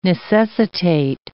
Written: necessitate

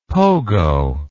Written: pogo

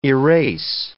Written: erase

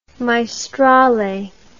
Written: maestrale

